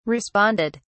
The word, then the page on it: responded: British English